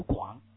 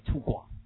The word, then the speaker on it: 粗犷: OK 我就这么干以前我认识都是这样的这个怎么读反正我读广粗犷 OK 现在对了吗就能粗犷粗犷啊人家现在以前说是可以读作粗犷但现在全部纠正过来粗犷 Right, OK, that's it.